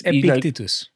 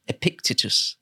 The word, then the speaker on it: Epictetus: I think it's Epictetus. – Epictetus.